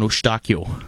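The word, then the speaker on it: Eustáquio: And if you want to go with the Portuguese pronunciation, it's Stefan Eustachio.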